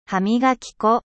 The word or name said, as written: dentifrice